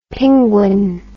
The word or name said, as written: pinguin